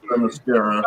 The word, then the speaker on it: Themyscira: Say it how it's spelled. – Yeah. I was going to say something about that, but he did say, oops, I meant Themyscira.